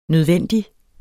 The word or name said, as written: nødvendig